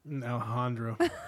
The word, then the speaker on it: Alejandro: Alejandro. The J is an H sound.